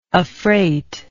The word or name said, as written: affreight